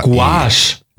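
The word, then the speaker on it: gouache: G-O-A-C-H-E. – Guache. – Guache. – Guache. – So spricht man es aus.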